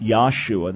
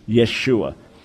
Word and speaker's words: Yeshua: So the way Jesus' name would have been pronounced was not Yahshua. That's a made-up name that folks have come up with incorrectly. But it would have been pronounced Yeshua.